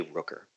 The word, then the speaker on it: Rooker: Rooker. – And last name, first name is spelled S-H-A-W-N, K as in kangaroo, Rooker, R-O-O-K-E-R.